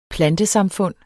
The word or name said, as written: PLANTESAMFUND